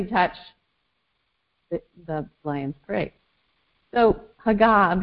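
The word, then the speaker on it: haga (הגה): And the Hebrew word for to meditate is spelled H-A-G-A-H, haga. And you kind of have to do this kind of growl thing going on in your throat, haga, like that.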